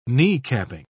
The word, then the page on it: kneecapping: British English